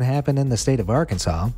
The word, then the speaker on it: Arkansas: That's right, according to Title I, Chapter 4, Section 104 of the Arkansas Code, there's only one right way to pronounce the state name, and that's Arkansas. Not Arkansas or Arkansas. Those would both be considered illegal. According to the rule, the correct pronunciation of Arkansas with the final S being silent was made official by an act of state legislature in 1881.